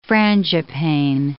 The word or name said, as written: frangipane